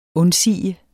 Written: undsige

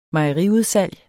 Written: mejeriudsalg

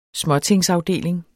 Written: småtingsafdeling

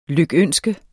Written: lykønske

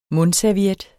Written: mundserviet

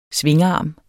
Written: SVINGARME

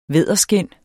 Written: vædderskind

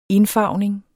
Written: indfarvning

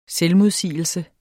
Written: selvmodsigelse

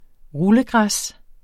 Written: rullegræs

